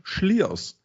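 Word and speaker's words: Slyrs: Also den Äppler haben wir, ich habe einen Andechser-Kloster und das letzte Mal tatsächlich habe ich einen Whisky angetrunken gehabt, der hieß Slyers, so hatte ich das gesagt, stimmt aber gar nicht, der heißt Schliers. So wird das Ding ausgesprochen. – Also Slayer. – Slayers im Prinzip. – Genau, Slayer. – Vom Schliersee kommt der Whisky. – Ah, okay. – Deshalb heißt der also Schliers wie Slayer geschrieben.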